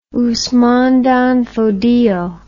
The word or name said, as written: Usman dan Fodio